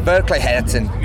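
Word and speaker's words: Verkligheten: So you guys released the album, let me try and get the pronunciation right, Verkleiten, earlier this year. – Verkleiheten. Yes.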